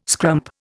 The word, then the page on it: scrump: Indian